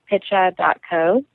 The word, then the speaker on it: Picha: P-I-C-H-A. Yes. Picha. So, it is Swahili for picture.